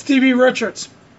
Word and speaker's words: D.B. Richards: It's D.B. Richards.